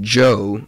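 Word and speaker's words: Djo: But now he's putting out his own music under the name Joe with a silent D at the beginning.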